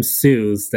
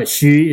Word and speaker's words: Hsu: And I just want to congratulate you for being the very first white person that pronounced my last name correctly. I have so much William Sue's that I kind of just don't mind anymore. But Xu is a very hard word to pronounce for Caucasians.